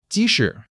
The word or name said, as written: ji2 shi3